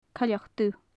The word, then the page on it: coileach dubh: gaelic